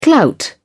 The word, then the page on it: clout: British English